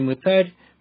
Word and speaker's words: мытарь: В слове «мытарь» прямо-таки стоит ударение на последнем слоге. Проверьте на досуге, так ли это.